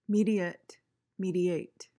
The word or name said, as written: mediate